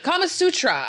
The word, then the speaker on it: Kama Sutra: And in Kamakutra. Kamakutra? Why am I saying this wrong? – I don't know. – Wait, Kama Sutra.